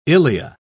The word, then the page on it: ilea: British English